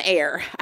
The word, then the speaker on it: Aire: Air spelled like Air A? – A-I-R-E, and then okay. She confirmed that the pronunciation is just Air.